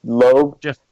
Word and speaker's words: Loeb: Loeb? – Loeb.